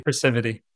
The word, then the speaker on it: Precivity: In particular, when we refer to C2N, you know, your Precivity, am I saying that right? Precivity? – Precivity.